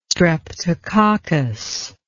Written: streptococcus

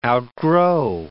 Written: outgrow

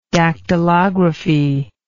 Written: dactylography

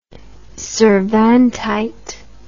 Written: cervantite

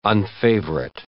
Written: unfavorite

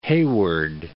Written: Hayward

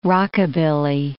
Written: rockabilly

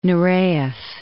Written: Nureyev

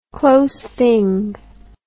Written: close thing

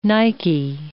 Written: Nike

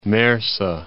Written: Marsa Matruh

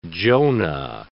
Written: Jonah

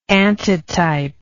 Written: antitypically

